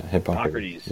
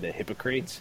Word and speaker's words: Hippocrates: I mean, like it's got its roots way back to Hippocrates. – Hippocrates. Yeah, you're right. – Yeah. Every time I say it, I want to say the like joke phonetic pronunciation, the Hippocrates.